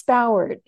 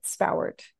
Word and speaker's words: Spowart: Yeah, it's Spowart. It's a little Spowart. Unusual. Apparently it's Scottish in origin, but Spowart.